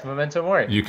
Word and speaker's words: Memento Mori: Yes, Memento Mori.